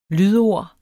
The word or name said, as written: lydord